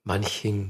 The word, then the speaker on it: Manching: Manching.